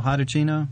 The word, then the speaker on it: Hitachino: It says it's Hitachino. – That's perfect. Yeah. It's riveting. Exactly right pronunciation.